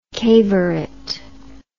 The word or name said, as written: cavorite